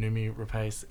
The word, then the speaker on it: Rapace: Both the actors that you've worked with in your past two features, Nicole Kidman and Noomi Rapace, am I pronouncing her last name? – You right? – are. Thank you.